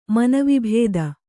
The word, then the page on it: ಮನವಿಭೇದ: manavi bhēda